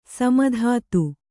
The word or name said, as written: ಸೀಮಿತತೆ